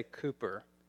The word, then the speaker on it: Cowper: We spell it Cowper, the Brits say Cooper.